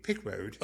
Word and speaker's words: Pickwoad: P-I-C-K-W-O-A-D. Pickwode.